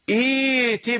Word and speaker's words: ائت: إذا بدأت بالكلمة يعني أنت عند الوصل لا يرجون لقاء نأتي، إذن نطقت بالهمزة، بالهمزة التي بعد همزة الوصل وحذفت همزة الوصل، فإذا قيل لك ابدأ بالكلمة بهذه الكلمة ستبدأ بصوت همزة الوصل، إذن ستنطق همزة الوصل، إذن الثانية لابد أن تسهل. إذا كنت في مسابقة أو امتحان وطلب منك أن تقرأ تبدأ بهذه الكلمة، أن تبدأ بهذه الكلمة فقل إيتي بقرآن، ولا تزد على ذلك، لا تقرأ إيتي بقرآن غير هذا، لا تقرأ هكذا، البعض يقع في هذا الخطأ.